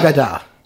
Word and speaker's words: Haggadah: Haggadah.